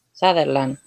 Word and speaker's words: Sutherland: ¿Cómo es que se pronunciaba? ¿Sutherland? – Sutherland.